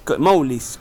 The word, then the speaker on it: Mowglis: Mowglis, ¿cómo se dice? – Mowglis. – Es como Mowgli, el del libro de la selva.